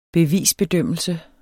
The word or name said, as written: bevis